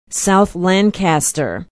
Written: south lancaster

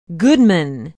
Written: Goodman

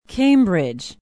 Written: cambridge

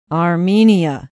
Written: armenia

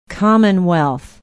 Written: Commonwealth

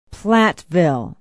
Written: platteville

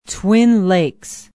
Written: twin lakes

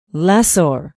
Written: lessor

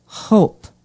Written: hope

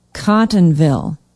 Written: Cottonville

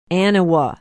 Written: aniwa

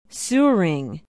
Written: suring

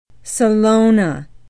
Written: Salona

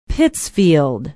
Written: pittsfield

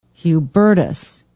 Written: hubertus

